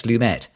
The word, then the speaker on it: Lumet: First witness for the defense is going to be Henry Fonda in a Sidney Lumet film. Did we decide is it Lumet or Lumet? It's Lumet.